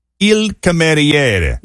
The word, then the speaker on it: il cameriere: C-A-M. – Yes, E-R. – Ed. – I-E-R-E. – Ray. – So it's pronounced il cameriere. – Il cameriere.